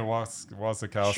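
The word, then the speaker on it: Wasikowska: And this one, I can't even pronounce, Mia Wasikowski? – Yeah, Mia Wasikowski.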